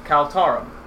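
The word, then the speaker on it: Khal-Tarum: K-H-A-L hyphen T-A-R-U-M. Yes, you are located in the dwarven capital of Kaltarum.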